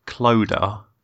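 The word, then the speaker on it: Clodagh: The next one looks Welsh and is apparently pronounced Cloda.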